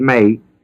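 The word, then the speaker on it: may (μή): For when, thee is scratched in the critical Greek text, for when Gentiles, which have not, and there it's the word may, M-E, long E, have not, thee is scratched again in the text, law.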